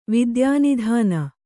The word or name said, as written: ವಿದ್ಯಾನಿಧಾನ